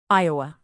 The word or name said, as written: iowa